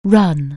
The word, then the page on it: run: anglais